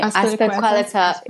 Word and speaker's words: Azpilicueta: Azpilicueta.